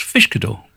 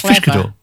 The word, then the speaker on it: Uiscedwr: It's spelled U-I-S-C-E-D-W-R. – That's it, yeah. But it's pronounced Fishkadoor. And do you know how I know that? – Go on, tell me. – Because they named their album after how they pronounce their name, and the album's called Fish Cat Door. – Wow, there you are. – So it's Fishkadoor.